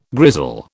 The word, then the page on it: grizzle: British English